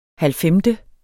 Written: halvfemte

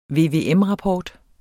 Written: VVM-rapport

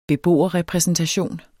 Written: beboerrepræsentation

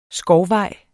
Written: SKOVVEJ

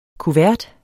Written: Kuverten

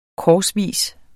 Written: korsvis

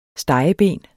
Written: stegeben

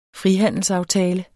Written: frihandelsaftale

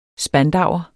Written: spandauer

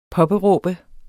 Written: påberåbe

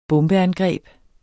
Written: bombeangreb

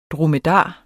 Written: dromedar

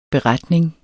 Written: beretning

